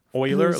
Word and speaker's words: Euler: Euler, like O-I-L-E-R, Euler? – Well, it's spelled E-U-L-E-R, but he was Swiss.